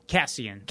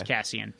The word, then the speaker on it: Cassian: Is it pronounced Cassian or Cassian? – Cassian. – Cassian? Okay. – Yes, Cassian.